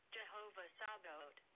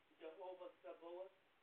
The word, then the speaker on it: Jehovah Sabaoth: Jehovah Sabaoth. – Okay. Some say Jehovah Sabaoth. Some say Jehovah Sabaoth. I always say it Jehovah Sabaoth.